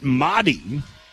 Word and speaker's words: Mari: You say it Madi, you spell it M-A-R-I.